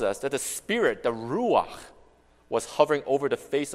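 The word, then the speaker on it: ruach (רוח): And similarly, in Hebrew, the word for spirit is ruach.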